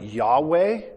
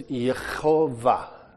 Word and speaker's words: Yehovah: It's also, some people pronounce it Yahweh, which is incorrect because there's not a W sound in the Hebrew alphabet. So it is Yehovah.